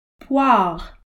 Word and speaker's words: Poire: Poire, pear.